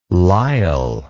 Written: lyle

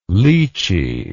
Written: lichee

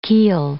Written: keel

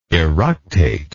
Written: eructate

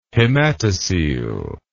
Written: hematocele

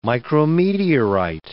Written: micrometeorite

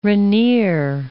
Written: Rainier, Mount

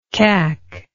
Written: cack

